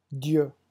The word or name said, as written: dieu